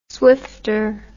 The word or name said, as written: swifter